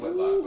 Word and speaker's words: Whitlock: I mean, Whitlock. I mean, no, you said it right. – You said it correctly.